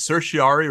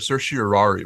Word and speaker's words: certiorari: So there's a debate among lawyers whether it's certiorari or certiorari, whether it's four syllables or five.